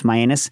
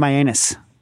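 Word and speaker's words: Mianus: Myanus. It's pronounced Myanus, I think. We always call it Myanus. You're supposed to turn to whoever's next to you. – No, I think it's Myanus.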